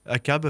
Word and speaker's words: Achab: Mais j'ai une écoute, je, vais vous proposer un truc, ça donc c'est ACAB, euh, un groupe qui s'appelle ACAB, ACAB pardon, euh, qui s'écrit A-C-H-A-B, comme le capitaine ACAB de Melville.